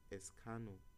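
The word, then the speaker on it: Escanor: So the number one is Encarno. Okay, Escano. I've always pronounced it as Encarno.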